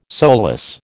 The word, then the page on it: solus: American English